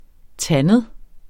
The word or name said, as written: tandet